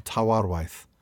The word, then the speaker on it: Tawarwaith: I can't pronounce this. Tawar... That's the W's that messed me up. Tawarwaith? – Is it Tawarwaith, I think? – Tawarwaith. There we go.